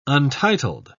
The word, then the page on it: untitled: British English